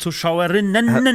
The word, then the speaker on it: ZuschauerInnen: Hallo, alle innen bitte nicht vergessen, hinten dran, hinter Zuschauer und Zuschauerinnen. – Das ist vollkommen richtig. – Und Zuhörerinnen. – Und man betont es ja wirklich, liebe ZuschauerInnen. Das ist die das ist die richtige Betonung, habe ich gelernt. ZuschauerInnen.